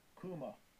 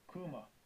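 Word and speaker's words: Kuma (κῦμα): Strong's G, 2949, Kuma. Kuma.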